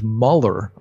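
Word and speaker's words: Müller: Now, you're German, so you know the right pronunciation. That's George Mueller, I believe. But everybody in the West, most of the people in America call him George Mueller.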